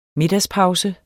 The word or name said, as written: middagspause